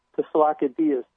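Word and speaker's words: Tsalikidis: It's pronounced, in Greek, I think it's pronounced, Tesalakidias. And he was it's Kostas Tesalakidias, and he was a very experienced engineer.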